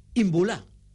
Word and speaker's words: Imbula: Es un pedazo de portada de Superdeporte anunciando el interés del Valencia por Imbula.